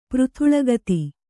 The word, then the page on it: ಪೃಥುಳಗತಿ: Kannada